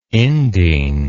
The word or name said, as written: indene